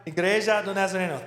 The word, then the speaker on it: Igreja do Nazareno: The Brazilian church plant, Igreja do Nazareno. Said that right? Igreja do Nazareno. Thank you.